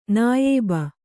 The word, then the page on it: ನಾಯಬ: nāyaba